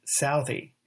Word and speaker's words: Southey: So the dedication is Byron's way of getting back at Bob Southey, or as it is sometimes pronounced, Southey. In fact, Byron himself rhymes Southey's last name with the word mouthy.